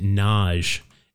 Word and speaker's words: Nagy: I remember, I was working in basketball, and there was a player whose last name was N-A-G-Y, but they pronounced it Naj, and it was, it just, I couldn't do it. I'm just so used to saying Nagy that I consistently say Nosh.